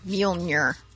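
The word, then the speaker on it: Mjölnir: Hey, that would be Mjolnir. – Oh, I like how you say it, too. Mjolnir.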